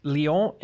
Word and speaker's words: Lyon: Today we are talking about the martyrs of Lyon Lyon and Vienne. – That's right. – I got the pronunciation right? You got it right.